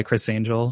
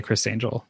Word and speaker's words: Chris Angel: So because I go by Chris Angel, trying to stand out, it's important to me that people do say Chris Angel together.